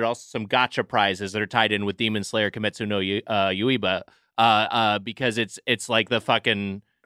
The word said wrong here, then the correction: Kimetsu no Yaiba: I think it's Kimetsu no Yaiba. I may have said it wrong.